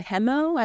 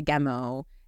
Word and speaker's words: Gemo: So you're in the forest, and there's this, the hemo, I don't know how you pronounce it. This demon or this sort of spiritual. – How do you spell it? – G-E-M-O.